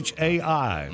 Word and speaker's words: chai: All right, and am I pronouncing it right? Chai? – I think that is how you pronounce it. – Chai.